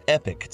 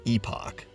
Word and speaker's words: Epoch: Oh, and feel free to read some real news by subscribing to the Epic Times. They say it's pronounced epic, but it's actually supposed to be pronounced epoch, the Epoch Times.